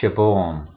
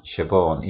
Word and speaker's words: Siobhan: El nombre es, se pronuncia Siobhan, Siobhan, y es un nombre galés.